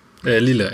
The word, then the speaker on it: Lille: Also ich gehe mal noch ins Weiter, noch mal kurz zum Ille zurück. Lille, Entschuldigung, jetzt habe ich es auch noch falsch gesagt.